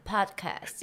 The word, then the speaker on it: Podcast: 强调我的英文发音，因为我的 podcast 有点被大家就是纠正。我是怕我念的太专业，大家听起来太阻拦。 – 啊、呃，因为记得是念 Pocket。 – 对对对对对，我想说就大家 Pocket Pocket 这样子，然后殊不知就是引发一些就是大家可能听起来那不是那么的舒服。 So 我现在就是会讲 Podcast，因为你的 ST 就是要发音啊。